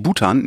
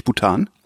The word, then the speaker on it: Bhutan: Sagt man wirklich Bhutan, nicht Bhutan? – Bhutan. – Bhutan. – Ich glaube, dass die Betonung ist auf dem U. Bin mir aber nicht wirklich sicher. – Weil das im Feuerzeug, also das Gas nennt man ja Bhutan. Also mit Betonung auf dem A.